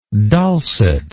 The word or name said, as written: dulcetness